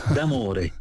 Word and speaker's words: D'Amore: Y entonces, mi apellido se pronuncia así como se lee, D'Amore, Bruno D'Amore.